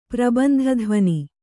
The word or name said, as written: ಪ್ರಬಂಧಧ್ವನಿ